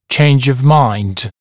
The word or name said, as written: change of mind